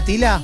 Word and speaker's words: Tyla: Es T-Y-L-A. – Sí, la estamos viendo. – Debe ser Tila, entonces. – Tila. – Tila. Jovencita. – Usted dígame mi pronunciación que yo... – ¿Es esta, Tila? – Sí.